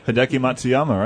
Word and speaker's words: Hideki Matsuyama: Hideki Matsuyama, right? – Yeah.